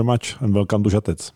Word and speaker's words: Žatec: Did I say it correctly? – Yeah, that's great. I mean, great pronunciation. It's Žatec.